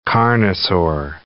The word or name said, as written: carnosaur